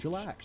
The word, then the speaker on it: Shellac: Shellac.